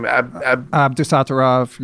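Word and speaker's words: Abdusattorov: How do you say that? Nota, whatever his name, Abdusatarov. Yeah. – That. Thank you for saying that.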